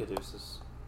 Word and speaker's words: caduceus: Caduceus? – Yep. I didn't know how to say that, so. – Yep, thank you. Caduceus.